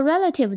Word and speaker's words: relative: 我们回到 relative 这个词，这个词也是三个音节，重音在第一个音节 r。那我们先通过读音来记忆一下这个单词，前面两个字母 R-E。 e 这个元音在这里发成 a，所以前面两个字母 r e 呢就发成 red，和 red 的 red 发音一样。